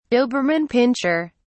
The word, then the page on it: doberman pinscher: British English